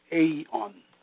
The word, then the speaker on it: aeon: The Greek word for world in this text is aeon, which pronounced aeon, meaning an age or the world.